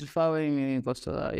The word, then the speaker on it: Fawehinmi Oyinkansola: It's Fawehinmi Oyinkansola, yes. Foza is different from Fawehinmi Oyinkansola.